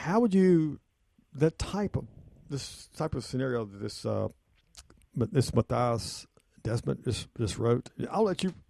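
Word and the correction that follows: Mattias Desmet: I believe it's Matthias Desmet, because it's a Belgian name.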